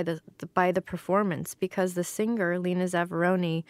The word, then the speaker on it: Lena Zavaroni: Oh, Lena Zavaroni. – Lena Zavaroni and her cover version of a Neil Sedaka song called Going Nowhere.